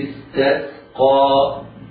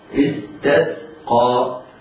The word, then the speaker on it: استسقى: السين في كلمة استسقى سينان ساكنتان، على القارئ أن يحترز من تفخيمهما، وإنما ينطقهما ينطق كل منهما سينا ساكنة مرققة، فلا يفخمها حتى لا تبدل صادا.